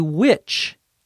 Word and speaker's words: witch: Courtney says, or else, Christopher finally figured out, finally realized, what a witch Kate is. A witch, W-I-T-C-H, which is pronounced just like another word spelled different, W-H-I-C-H.